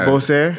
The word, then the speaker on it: Labossiere: I said Labossiere and yeah, Labossiere? – Exactly. Labossiere. – Labossiere.